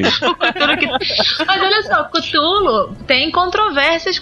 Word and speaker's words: Cthulhu: Porque ninguém sabe exatamente qual é a pronúncia. Porque Lovecraft diz que é um nome impronunciável. O mais próximo é Clulo e não Cthulhu.